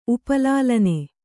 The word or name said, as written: ಉಪಲಾಲನೆ